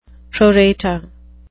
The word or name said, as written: pro rata